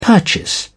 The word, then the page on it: purchase: British English